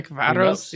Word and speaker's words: Ferencváros: Ferencváros. – Yeah, I probably pronounced that wrong. Or right? – Ferencváros, yeah.